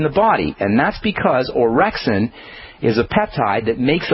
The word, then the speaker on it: orexin: It's a peptide called orexin, and that's spelled O-R-E-X-I-N, orexin.